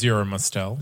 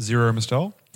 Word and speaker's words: Zero Mostel: Zero Mostel. – Zero Mostel.